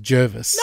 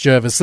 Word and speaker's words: Jervis: You've been saying Jarvis for a long time. – Yeah, isn't that how you say it? – No, his name is Jervis. – No, it's not. – It's written Jervis. It's with an E, and it's pronounced Jervis.